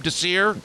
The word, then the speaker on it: Desir: and Pierre Desir, is that how I pronounce his name, Desir? – Yeah.